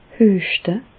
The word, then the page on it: hüeschte: Bas-Rhin; Reichshoffen